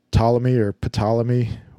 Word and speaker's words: Ptolemy: And he goes into detail about, you know, Copernicus and Aristotle and all those guys, Kepler, Ptolemy with the silent P, if it's silent at all.